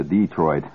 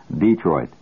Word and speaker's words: Detroit: I just flew out to Detroit That's the way they pronounce it out there. Detroit.